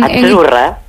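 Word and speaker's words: Azzurra: La zeta no es la zeta como es... decimos en catalán tan sonora, no. – Ah, es achurra. – Muy bonito, muy bonito. – Es como pizza.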